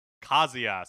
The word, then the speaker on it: Kazuya: myself because I knew that we were going to be saying it, and I uh, I was reading it all wrong until like Sakurai comes on and s- says uh, Kazuya. So I think that's how you pronounce it.